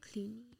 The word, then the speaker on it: Clooney: C-L-O-O. Clue. What sound is C-L-O-O? – Clue. – Clue. Clooney. Boom. So what is Clooney. – It? – George, nailed it. – George Clooney.